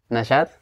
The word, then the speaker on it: Najat: Hey, welcome everybody and welcome, Najat, to another podcast of The Press to Success, wherein I have real talks with real people, just like Najat. I hope I pronounce it correctly. – Yes, it's Najat.